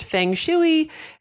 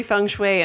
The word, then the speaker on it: Feng Shui: It looks like it's pronounced Feng Shui. It's actually Feng Shui,